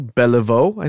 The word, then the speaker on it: Beliveau: Now, let's see, this guy's name, Beliveau, B-E-L-I-V-E-A-U, Beliveau, I think that's how you would say that.